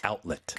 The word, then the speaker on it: outlet: Outlet. – 그 outlet 해도 돼요? – Outlet 하면 이해는 할 것 같은데 네. 발음이 조금 틀리죠. Outlet.